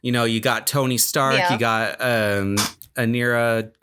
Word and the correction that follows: Ariana: Grande. Honorary Grande. – Ariana.